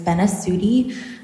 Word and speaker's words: Benasutti: For the record, my name is Grace Benasuti. That's spelled B-E-N-A-S-U-T-T-I.